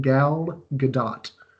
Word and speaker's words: Gadot: It is pronounced Gal Gadot, short O and an audible T sound.